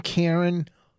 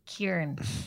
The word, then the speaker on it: Kerryn: It is. It's Karen. – It's Karen. – Oh, I always been getting that wrong. – It's because it's spelled K-E-R-R-Y-N. You always say Kieran.